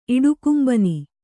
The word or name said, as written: ಇಡುಕುಂಬನಿ